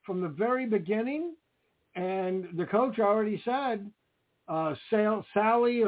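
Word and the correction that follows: Saleh: I don't know how to really pronounce his name. – Salah.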